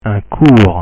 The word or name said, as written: un cours